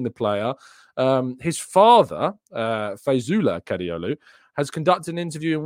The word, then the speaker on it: Kadıoğlu: Ferdi Kadıoğlu. I don't think you pronounced the G.